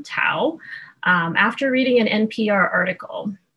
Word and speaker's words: Thao: So obviously to most of us, the name Tao is unfamiliar. It's spelled T-H-A-O, but it is properly pronounced with a silent H.